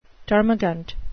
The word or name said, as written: termagant